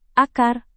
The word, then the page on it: akar: indonesian